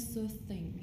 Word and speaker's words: soothing: Oh, itu shooting, Itu oh, okay. ya. – Ini soothing. uh, sih bacanya gitu.